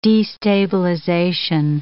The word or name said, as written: destabilization